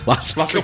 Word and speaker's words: Lasse: I will learn more next week, as I will of A Dog's Purpose, the latest by Lasse Hallström. – Is it actually pronounced Lassie? Because if it is, that's a perfect meeting of material and director. – It's Lasse, isn't it? Lasse Hallström. Not Lassie Hallström.